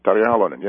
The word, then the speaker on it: Tarja Halonen: Your nation has a woman as president, Tarja Halonen. Am I pronouncing that right? – Yes, Tarja Halonen, yes.